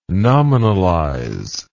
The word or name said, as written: nominalize